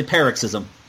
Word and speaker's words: paroxysm: Okay, so you're actually one of the first people to pronounce it correctly. It's actually paroxysm.